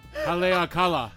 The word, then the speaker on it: Haleakala: Hand-picked on the slopes of Halakala. The fruit is pressed. – I can pronounce it because I've been there before. It is actually pronounced Haleakala.